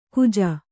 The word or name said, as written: ಖೋಜಾ